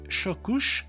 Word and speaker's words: chocos: Vous avez également les chocouches. On écrit chocos, mais on prononce chocouche.